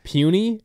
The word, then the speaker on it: Puni: Because offensive lineman with the last name, it's spelled P-U-N-I, but pronounced Pooney.